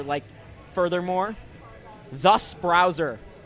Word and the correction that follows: Tyus: That's another one that's just, no, it's Tyus. – It's spelled T-H-U-S. – No, T-Y-U-S.